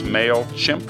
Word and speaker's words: MailChimp: And a big thank you to MailChimp, that's pronounced MailChimp, for sponsoring the observatory.